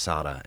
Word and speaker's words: Sara: It's spelled Sara, but pronounced Sada.